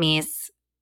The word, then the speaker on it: Myss: And it was coming in and listeners, just Google Carolyn Meese. That is how you say her name, I think. M-Y-S-S, Sacred Contracts, and your mind will be blown.